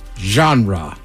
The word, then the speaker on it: genre: Genre. – What do you think, Ted? – French? – Nailed it. Pronounced the French way, this word doesn't get the English treatment.